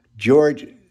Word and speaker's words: Georg: George Scherf Sr., or Prescott Bush, and George Scherf Jr., that's actually Georg, because Germany, and it's pronounced as Georg in German, but as we pronounce it as just George.